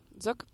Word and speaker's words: Czuk: I think it's Zook. – Zook.